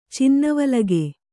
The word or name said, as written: ಚಿನ್ನವಲಗೆ